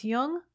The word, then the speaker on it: Jung: Just be sure to pronounce Jung with a hard J.